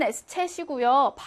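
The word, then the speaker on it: Eszett: s챗이라는 게 있어. 이름은 s챗이고요. 발음은 그냥 스. s처럼 스로 발음하면 됩니다.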